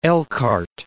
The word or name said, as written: Elkhart